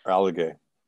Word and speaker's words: Alleghe: I pronounced it like a Canadian would say it. I like to pronounce the names, but I don't know Italian. I could say it if it was a German word, but I don't know. How do you say it? – Alleghe.